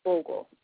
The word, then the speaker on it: Bogle: His name is Paul Bogle, P-A-U-L, and the last name is Bogle, which is B-O-G-L-E, and he is from Morant Bay, which is M-O-R-A-N-T.